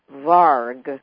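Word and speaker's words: vargr: And you say it's undoubtedly of Saxon or Germanic or Viking origin, which, you know, and you say it's deriving perhaps from the Old Norse varg, or maybe I'm not pronouncing it, it's V-A-R-G-R. – You're pronouncing it very well. – All right. And then the German Wager, or W-A-G-R.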